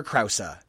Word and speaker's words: Krause: He did a commercial for Parenthood with Lauren Graham, and in it he says, I'm Peter Krause. – And we've never heard the E pronounced so hard in the last name Krause, so now we make sure that we get it every time just for him.